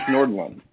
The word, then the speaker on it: Nordlum: I always tell people it's like Nordstrom, but it's Nordlum.